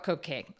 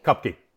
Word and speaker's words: Kopke: So first, I'd love to hear more about Kopke. Or Kopke. I'm mispronouncing it, right? – Kopke. Kopke. They in Portugal they say Kopke, but in the States we just say Kopke.